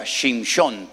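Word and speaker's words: Shimshon (שמשון): En hebreo se pronuncia Shimshon, porque viene de la palabra Shemesh, que significa el sol.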